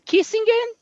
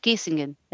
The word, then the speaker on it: Kissingen: Então, hoje ela mora numa cidade chamada Bad Kissingen.